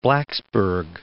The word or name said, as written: Blacksburg